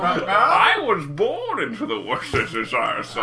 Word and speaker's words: Worcestershire: The correct pronunciation is Worcestershire.